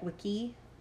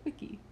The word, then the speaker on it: wicce: It's something like wiki. – Like wiki. – Like a wiki. Like a witch wiki. It's spelled W-I-C-C-E.